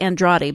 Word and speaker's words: Andrade: The first person I want to bring up is Joanna Jędrzejczyk, and she is set to fight Jessica Andrade. – Thank you. I can never pronounce that. I look at the word and I can never remember how to pronounce it. Jessica Andrade.